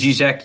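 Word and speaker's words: Žižek: By the way, is it Zizek, not Zizek? Have I been saying it wrong? – Honestly, it is Zizek. You're saying it right.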